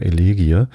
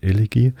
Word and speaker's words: Elegie: Ich soll an Melodie denken, wenn ich Elegie ausspreche. Ich hadere immer so ein bisschen, wie spricht man eigentlich dieses Wort Elegie aus? Ich sage immer Elegie, was dann vollkommen falsch ist. Man sagt ja auch nicht Melodie. Und mit dieser Eselsbrücke, Melodie, Elegie, komme ich klar.